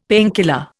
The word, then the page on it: painkiller: Indian